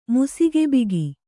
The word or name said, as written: ಮುಸಿಗೆಬಿಗಿ